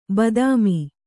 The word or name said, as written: ಬಾತ್ಮಿ